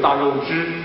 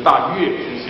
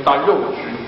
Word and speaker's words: 月支: 当时的乌孙人就可能是还大，就所谓的大肉支或者大月支写成月，又要为了有学问要念成大肉支。